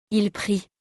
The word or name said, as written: il prie